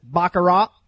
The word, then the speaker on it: baccarat: Attendees will learn how to deal blackjack and baccarat. I've heard some people pronounce it baccarat without the T. I put the T on the end.